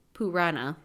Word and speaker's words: Purana: Uh, so the main Puranic resource on ghost and death related information is Garuda Purana or Pur- Purana. There we go. I just got to keep thinking it's like a weird pronunciation of Purana.